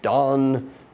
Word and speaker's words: Dan: Therefore she named him Dan, or Don, depending on the pronunciation, and it means judge.